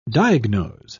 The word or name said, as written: diagnose